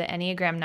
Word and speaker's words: Enneagram: And then there's characteristics associated with each of the Enneagram numbers that are going to like show more in that time.